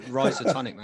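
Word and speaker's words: Rhizotonic: Rizotonic, man. – Rizotonic, there we go. – Rizotonic. Yeah. That's what I assume it is with the RH, like rhino.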